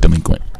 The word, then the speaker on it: Dlequint: All right, before go, can you say it's pronounced delinquent, bitch? – No.